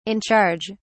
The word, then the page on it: in charge: British English